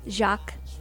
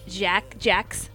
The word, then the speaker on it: Jacques: Anyway, it was the one that was translated from the French poet Jacques. And I said Jax.